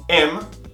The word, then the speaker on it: am: Am, is, are. Atente à pronúncia. Não é an, é am. Feche os lábios.